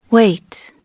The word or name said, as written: weight